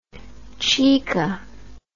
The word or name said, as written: chica